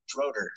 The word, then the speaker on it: Schroeder: But my special guest for this evening is Colt, and I'm going to butcher your last name probably, Schroeder? – Schroeder. – Schroeder, there you go. See, I knew I probably would not say that right.